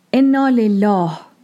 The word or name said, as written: Ennā-Lellāh